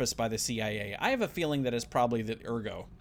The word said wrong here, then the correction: ergot: Ergot. – Ergot. – Yeah, that one is not pronounced. – I was on the French kick. – Yeah, it's not pronounced all Frenchy.